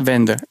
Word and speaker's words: Wende: Zeg ik dat goed? Ja, Wende. Ja.